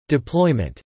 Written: deployment

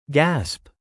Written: gasp

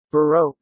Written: Baroque

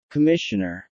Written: commissioner

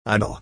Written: idyll

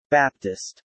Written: Baptist

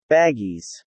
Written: baggies

